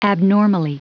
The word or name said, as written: abnormally